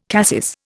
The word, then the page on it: cassis: Indian